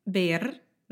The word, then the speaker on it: Bernardes: Bernardes, que também acho difícil por causa dos R's, porque tem dois, né? Tim Bernardes. – Ou Bernardes. – Ou Bernardes. – É, depende muito do sotaque, mas pode escolher qualquer pronúncia, qualquer sotaque e tá tudo bem.